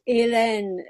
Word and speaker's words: Hélène: Yes, Hélène, the H would be silent, but it's there.